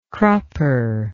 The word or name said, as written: crupper